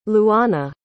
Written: luana

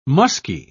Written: musky

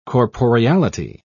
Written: corporeality

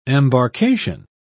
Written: embarkation

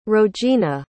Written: rogena

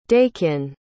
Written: dakin

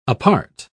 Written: apart

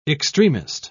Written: extremist

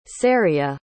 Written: ceria

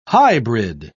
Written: hybrid

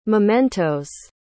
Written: mementoes

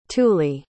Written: thule

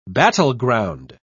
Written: battleground

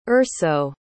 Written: urso

